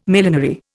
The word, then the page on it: millenary: Indian